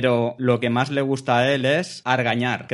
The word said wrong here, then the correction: argallar: Creo que lo he dicho bien. – Argallar. – Argallar. – Con, con ella. Ah, vale, vale, vale. Casi, casi, casi me sale.